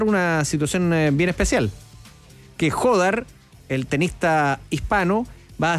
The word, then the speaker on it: Jódar: Se trata del señor Rafael Jódar, así se pronuncia, Rafael Jódar, el español, que es 11 del mundo con 19 años.